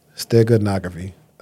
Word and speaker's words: steganography: Stenography. – Steganography. S-T-E-G-A. N-O, Stega. Yeah, S-T-E-G-A. G-R-O.